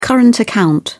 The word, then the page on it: current account: British English